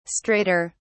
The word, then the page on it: straighter: British English